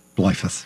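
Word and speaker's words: Bleifuss: How do I say it? – Blyfus. – Oh, I did say it right.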